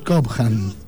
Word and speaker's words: Cobham: Estábamos discutiendo cómo se pronunciaba el apellido. – En realidad, a mí me parece que el apellido es Cobham, pero como suena medio como raro... – Tira por la H a hacer el ham.